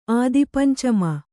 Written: ಆದಿಪಂಚಮ

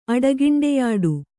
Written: ಅಡಗಿಂಡೆಯಾಡು

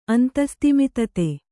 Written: ಅಂತಸ್ತಿಮಿತತೆ

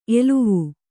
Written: ಎಲುವು

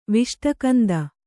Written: ವಿಷ್ಟಕಂದ

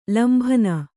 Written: ಲಂಬನ

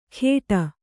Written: ಖೇಡ

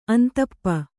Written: ಅಂತಪ್ಪ

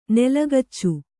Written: ನೆಲಗಚ್ಚು

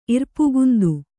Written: ಇರ್ಪುಗುಂದು

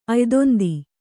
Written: ಅಯ್ದೊಂದಿ

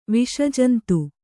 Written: ವಿಷಜಂತು

